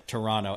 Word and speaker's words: Toronto: Toronto. Wait a minute. Toronto. I can't believe I pronounced the T. I should get docked major points for that.